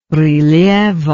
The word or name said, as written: rilievo